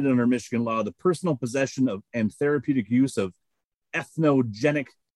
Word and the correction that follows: entheogenic: plants. Did I say that correctly? – Entheogenic.